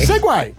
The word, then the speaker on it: Segway: Segway.